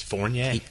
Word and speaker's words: Fournier: it Fournier? Yeah, it's Fournier. – Fournier. It's I, I knew I wasn't saying it right. Took me a while. It's not a beignet; it's a beignet, mm, and that's Fournier.